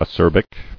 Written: Acerb

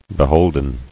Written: Beholden

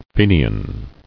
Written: Fenian

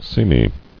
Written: Seamy

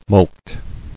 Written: Mulcted

